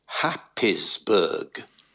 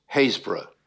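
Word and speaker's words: Happisburgh: Now, it's spelt Happisburg. – Right. – It's pronounced Haysborough.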